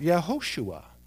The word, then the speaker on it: Yehoshua: And the first one was Jesus, which is the name we know the Christ by, Jesus, which is a form of the Hebrew name Joshua, which is actually pronounced Yehoshua, which means Yahweh, or the Lord, is salvation.